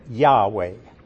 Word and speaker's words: Yahweh: And you would say it, Yahweh.